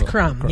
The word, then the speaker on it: Krum: Is it Krum? – It's Krum, yes. – But the Germans pronounce it Krum.